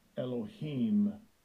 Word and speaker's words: Elohim: Second entry. Elohim. Elohim. And the third entry. Eloah. Eloah.